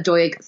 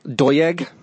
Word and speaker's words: Doig: Got a bit of an awkward surname. It's D-O-I-G. Hugh, do you want to pronounce how you said it earlier? – Doig. – Doig Thorne. I went American. I cannot do a Welsh accent. D-O-I-G hyphen Thorne on all of the socials.